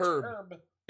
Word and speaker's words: herb: It's herb. It's herb. – Yeah. – ERB. H is silent, unless you're British.